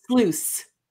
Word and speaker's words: Sluys: Well, if you think about like a super sleuth. – Yes. – Yeah. But it's Sluys.